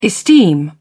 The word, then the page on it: esteem: British English